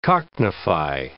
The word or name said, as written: cockneyfy